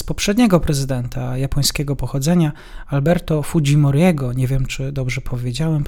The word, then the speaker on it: Fujimori: Zaraz poprawię na, na, na, na wzór y, y, hiszpański, prawda, bo nam się kojarzy Fujimori, ta wymowa taka bardziej japońska, ten jest Fujimori powiedzmy, że tak jest nazywane.